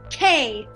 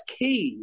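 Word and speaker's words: quay: That's key. It's not K.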